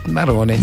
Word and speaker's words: marrone: ¿Cómo se dice en italiano? Morone. – Marrone. – Marrone, sí. ¿No? – Que es de color marrón o de café.